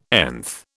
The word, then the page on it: nth: British English